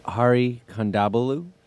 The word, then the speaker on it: Hari Kondabolu: As I may, it's Hari Kondabolu.